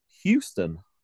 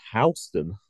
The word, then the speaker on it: Houston: I did say on the original interview that it was Houston, Delaware, because that's how it's spelled, like Houston, Texas. I've since found out that it's actually Houston, Delaware.